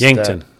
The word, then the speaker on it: Yankton: I don't know how to pronounce Yankton.